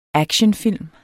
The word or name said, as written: ACTIONFILM